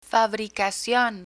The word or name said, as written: fabricacion